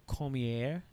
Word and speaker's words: Cormier: Ends up winning his match against Daniel Cormier. Did I say that right? – Yeah, oh, it's French. it's like Benoit.